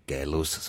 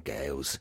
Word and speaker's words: Gaius: Ich heiße nicht Gaius, sondern Gaius. – Mhm. – Das machen insbesondere Schüler gerne, Gaius Julius Caesar zu sagen, aber es ist nicht richtig, ich heiße Gaius.